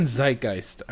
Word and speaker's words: zeitgeist: So I just thought it was weird that this is just in the zeitgeist again. – Did you guys maybe see it's fucking zeitgeist, alright? Say it right.